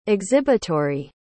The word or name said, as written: exhibitory